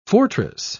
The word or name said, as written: fortress